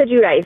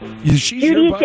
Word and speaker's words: Giudice: Teresa Giudice. – Very nicely done. I've never known. How do you actually pronounce it? – Giudice. Right? I don't know. – It's Giudice, right? – She goes back and forth.